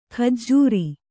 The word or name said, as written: ಖಜ್ಜೂರಿ